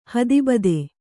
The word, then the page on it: ಹೇತುಭೂತ: hētu bhūta